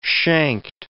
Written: shanked

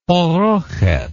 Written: parocheth

